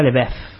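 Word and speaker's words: LaBeouf: Saya Lebef, este, por ejemplo. – Ah, ¿Cómo se pronuncia? – A ver, a ver. – Saya Lebev, porque es el, apellido es Francis.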